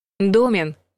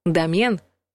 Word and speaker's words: домен: Домен — неправильный вариант. Домен — правильный вариант. Словари приводят до 10 разных значений слова. В историческом, биологическом, математическом, физическом контекстах. Наш вариант компьютерный. Домен или доменное имя — это имя сайта, те самые символы, которые мы пишем в адресной строке. Ударение падает на второй слог.